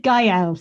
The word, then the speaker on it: Gael: On the commentary to this, Gondry's like, because I think I said it before, but Gael is like, he says Gael, so maybe I've been saying it wrong. Gael. Anyway, Gael, somewhere between, isn't it?